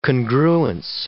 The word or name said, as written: congruence